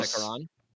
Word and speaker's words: Sekaran: Chandra Chandra Sekar or no? – No. Sekaran. Yeah, Sekaran.